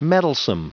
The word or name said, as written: mettlesome